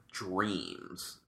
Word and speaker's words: Jream: So the next beer we're going to do is one of their Dreams. Now, this is Dream, not spelled with a D, but spelled J-R-E-A-M.